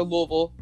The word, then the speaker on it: Louisville: But this week, we are heading to Louisville. Yes, that is how you pronounce it. I'm from Kentucky. Don't argue with me. It's Louisville.